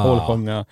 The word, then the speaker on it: Holsjunga: Det är hålsjunga när vi gör Holsjunga, jag vet inte hur uttalar det Hålsjunga. – Holsjunga.